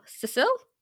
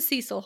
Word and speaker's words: Cecil: How would you pronounce C-E-C-I-L? Cecil is how I pronounce it. Does that sound right? – Cecil.